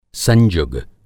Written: संजुग

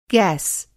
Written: गैस

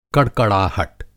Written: कड़कड़ाहट

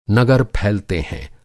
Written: नगर

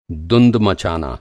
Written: दुंद

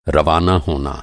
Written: रवाना

